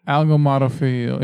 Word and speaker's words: agalmatophilia: What is agalmatophilia? – Ego. Still not aggro. – Agalmatophilia. – Agalmatophilia. – Agal. Yeah. – Agalmatophilia. – Yeah, not al. Not algal. It's agal. Agal.